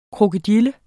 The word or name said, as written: Crocodylia